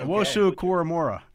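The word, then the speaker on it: Owusu-Koramoah: Owosu Koramora.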